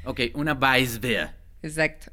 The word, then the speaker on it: Weissbier: Weissbier. – Que se escribe como Weissbier, pero pues en realidad si tú quieres llegar muy conocedor a la tienda, llegas y le dices, me das una Weissbier.